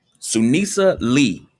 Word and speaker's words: Sunisa Lee: Sunisa Lee won gold in the all-around.